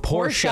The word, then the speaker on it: Porsche: Or it's Porsche. Porsche.